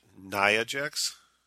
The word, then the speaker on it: Nia Jax: And then your friend, is it Nia Jax? – Yes, I finally said it right.